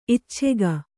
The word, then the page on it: ಇಚ್ಛಕ: icchaka